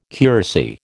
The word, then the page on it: curacy: American English